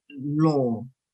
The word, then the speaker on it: Law: So his surname in Cantonese is pronounced Law. My mother said the character was the character for the word net, as in a fishing net. But it could have been transliterated into English as L-O, L-O-H, L-O-W, L-A-W, L-O-R.